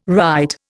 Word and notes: wright: Indian

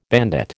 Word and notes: bandit: American English

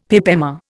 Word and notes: pip emma: Indian

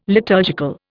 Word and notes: liturgical: Indian

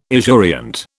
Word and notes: esurient: British English

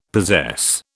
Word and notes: possess: British English

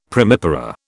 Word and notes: primipara: British English

